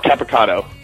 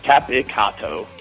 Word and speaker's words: Capicotto: Actually, how do you pronounce your last name? I'm sorry. – Oh, Mark Capicotto. – Capricotto, okay. – Capicotto. – Capicotto, yeah. Okay. – It's tough. It's super Italian, a lot of syllables.